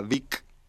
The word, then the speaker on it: Beak: Después otra banda que se llama Vic, que se escribe Beak con K y un chochito, no sé.